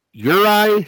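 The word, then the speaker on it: Juraj: The additions are Mike Matheson, another former Blackhawk from last year, Kirby Dach, Sean Monahan, Jiraj Slavkovsky. – Uri Slavkovsky.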